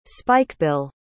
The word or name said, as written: spikebill